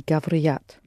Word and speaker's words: говорят: Они говорят. – Говорят. Pronounce the r soft, please. – Они говорят. – Говорят. There's no e after r. Говорят.